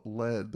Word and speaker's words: Led: And the fact that they didn't call it Led, the reason it wasn't spelled LED, like lead, is because Page said Americans will pronounce it lead.